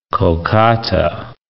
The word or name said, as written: Kolkata